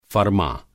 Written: फ़रमाँ

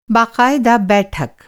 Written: बाक़ायदा

